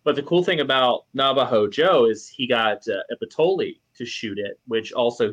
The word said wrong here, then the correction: Ippoliti: Silvano Ippoliti. There we go. – Ippoliti. That's how you say it.